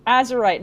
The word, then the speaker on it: Azurite: Azurath, can you launch the nukes? – Azurite, not Azurite.